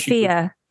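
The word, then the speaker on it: Fiadh: It's just Fia. Because it's Gaelic. – Yeah, I don't know how the hell you pronounce it, but okay. – Yeah, because the DH is silent.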